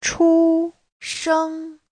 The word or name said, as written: chu1sheng1